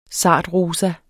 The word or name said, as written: sartrosa